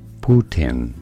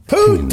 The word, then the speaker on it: Poutine: How do you say it? Poutin. – Oh! – Poutine! Poutine, baby!